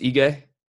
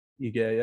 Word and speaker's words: Ige: Oh, it's Ige? – Ige, yeah.